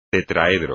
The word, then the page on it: tetraedro: espagnol